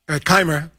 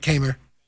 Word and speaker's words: Kaymer: I like that you corrected the pronunciation and he said it again. Kaymer. Yeah, right, Kaymer.